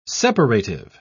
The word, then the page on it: separative: British English